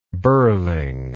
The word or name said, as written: birling